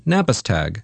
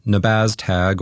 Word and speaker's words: Nabaztag: First things first, how does one pronounce this strange word? I wrote it phonetically in my notes the way its designer said it, roughly Navistag, not Nabaztag or whatever.